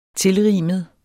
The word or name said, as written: tilrimet